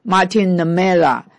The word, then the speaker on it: Martin Niemöller: 所以呢箴言 21 章 13 节预测了马丁莫尼拉，他的德文发音是 Martin Niemöller，